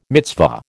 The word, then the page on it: mitzvah: American English